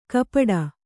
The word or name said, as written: ಗುಪ್ಪಟ್ಟೆ